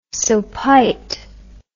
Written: sopite

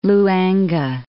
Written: Luangue

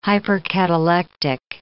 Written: hypercatalectic

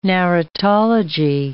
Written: narratology

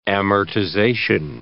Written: amortization

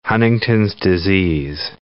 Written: Huntington's disease

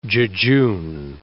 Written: jejune

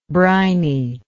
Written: brininess

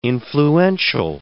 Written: influential

